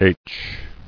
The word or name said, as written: Aitch